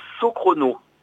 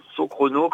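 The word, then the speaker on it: SoChrono: Alors, il faut aller sur le site Socrono. Socrono, comme ça se prononce, S-O et Chrono.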